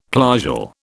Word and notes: plagal: British English

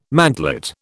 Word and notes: mantelet: British English

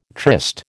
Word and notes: tryst: American English

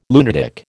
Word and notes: lunatic: American English

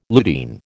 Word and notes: lutein: American English

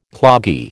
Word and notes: cloggy: American English

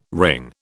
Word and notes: wring: British English

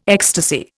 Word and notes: ecstasy: Indian

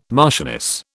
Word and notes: marchioness: British English

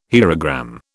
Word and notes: hierogram: British English

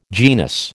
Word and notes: genus: American English

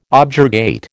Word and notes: objurgate: American English

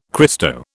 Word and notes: Christo: British English

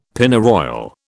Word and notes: Pennyroyal: British English

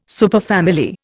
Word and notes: superfamily: Indian

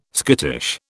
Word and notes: skittish: British English

